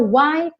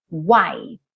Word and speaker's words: white: O E não é pronunciado e o T quase some. White my shirts can be. Porque eu já tô indo pro M, já tô fechando a boca pro M.